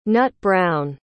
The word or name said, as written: nut-brown